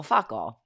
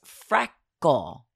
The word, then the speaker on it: freckle: So we just keep repeating fuckle, fuckle, fuckle. And I'm like, Viv, it's not that. It's freckle. But she can't even, A, she thinks it's funny, but she can't pronounce her R's great.